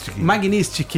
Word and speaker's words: Magnitsky: Ele pediu de novo a lei do Magistrink, né? Como é que fala isso? – O quê? – Magnitsky. Ele pediu de novo a lei Magnitsky.